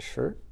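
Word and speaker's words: ji (事): Or actually that the poem refers to, the Japanese pronounce ri and ji, or the Chinese say li and shi.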